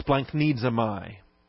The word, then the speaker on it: splanchnizomai: SPLANCHN. It's really GCHN. But you can't pronounce G-C-H-N, so they make it N-C-H-N, which is still hard to pronounce. I-Z-O-M-A-I, splanknesmi.